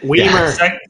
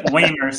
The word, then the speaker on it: Wiemer: No, what's interesting about that is the Ross Wiemer, really, he's to blame for this, first of all, not me. – Wiemer. – Wiemer, sorry. – Rhymes with Wiener. How could you ever? – Yeah.